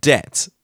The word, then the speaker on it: debt: The word debt with the silent B in it. I have to say debt.